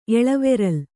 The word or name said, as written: ಎಳವೆರಲ್